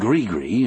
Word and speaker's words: Grigri: I grew up believing in Grigri as well. Only up in the hills we set it to rhyme with kiss, kiss.